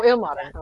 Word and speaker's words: Ilmater: Ilmater? Is that how you pronounce it? Is that what we... – Ilmater? – Ilmater. – Oh, Ilmater. Okay.